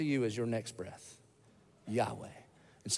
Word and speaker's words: Yahweh: It's just four letters, and it's in English, we pronounce it Yahweh, Yahweh. And in Hebrew, when you say it, it's supposed to sound like inhaling and exhaling. Yahweh.